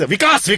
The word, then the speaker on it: Vikas: Our development is Vikas. Something badi cheez hai, Vikas. Divide that into two syllables. Vik-as.